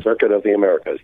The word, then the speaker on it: Circuit of the Americas: Is it Circuit de Americas? Is that it? Did I say that right? – Circuit of the Americas, yep.